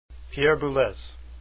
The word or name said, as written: Boulez